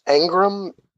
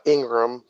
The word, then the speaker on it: Engram: My next pick is going to be, how do you pronounce this? Engram? It's not Ingram. – Yeah. – But it's E-N-G-R-A-M from the New York Giants.